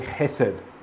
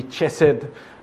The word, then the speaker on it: hesed: This word steadfast love is actually the word hesed. And we've heard it pronounced actually chesed.